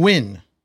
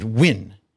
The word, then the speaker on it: Nguyen: I looked up the pronunciation and it's just did it tonight because I was tired of saying Nguyen or something. – Okay. – It said Win.